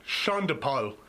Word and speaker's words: Sean Paul: Next up, we got Baby Boy, Beyoncé, and Sean DePaul. This is from 2003's Dangerously in Love. That is how you actually pronounce his name. Sean DePaul.